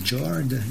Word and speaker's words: Jord: En fait, la prononciation en norvégien est très différente. De leur quatrième album, Jord, J-O-R-D.